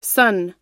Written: sunn

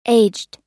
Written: aged

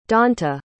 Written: donta

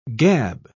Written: gab

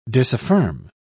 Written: disaffirm

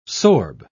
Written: sorb

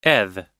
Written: eth

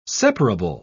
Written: separable

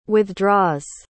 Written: withdraws

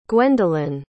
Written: gwendolen

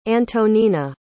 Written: antonina

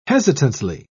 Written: hesitantly

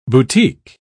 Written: boutique

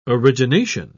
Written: origination

